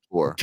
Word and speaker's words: DeBoer: Yeah, DeBoer.